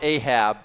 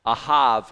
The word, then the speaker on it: Ahab: And it's not Ahab, it's Ahav, because the final, a B on the end of the word is usually pronounced V. Like in the name Yaakov, we say Jacob. It ends with a B, but it's the final form B, and it sounds like a V.